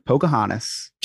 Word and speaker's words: Pocahontas: That's not how you say it. – There's a T at the end. – Poke your honest. – Pocahontas.